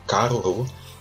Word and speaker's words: caruru: Dentro do tablet tinha um vídeo, duas pessoas, um vídeo da Ivete Sangalo falando sobre um kururu, que é caruru, que é um prato...